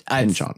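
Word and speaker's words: Pynchon: Pinchon. I've always mispronounced that name, turns out.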